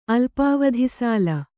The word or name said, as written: ಅಲ್ಪಾವಧಿಸಾಲ